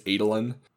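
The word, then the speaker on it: Adolin: I think the technically correct pronunciation is Adelin.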